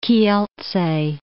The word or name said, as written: Kielce